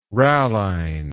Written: ralline

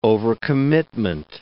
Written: overcommitment

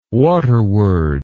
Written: waterward

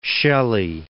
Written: Shelley

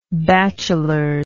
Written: bachelors

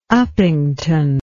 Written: upington

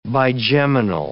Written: bigeminal

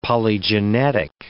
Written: polygenetic

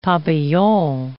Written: papillon

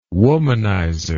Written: womanizer

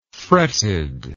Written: fretted

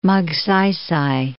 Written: Magsaysay

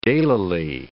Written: daylily

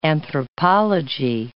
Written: anthropology

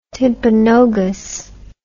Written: timpanogos

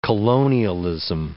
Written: colonialism

